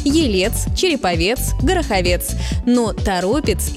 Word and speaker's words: Торопец: Один из таких примеров – Торопец из Тверской области, хотя в новостях можно услышать и Торопец, но это ошибка.